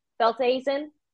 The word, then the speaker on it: Velthuysen: It is basically pronounced pretty phonetically, Velthuysen. You just get to throw in an accent there.